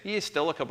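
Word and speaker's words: EASTL: I mean, we invented this EA Stelica. I guess I didn't say it. Estelica, by the way, is supposed to be pronounced like Metallica. And it's E-A-S-T-L I-Core Allocator.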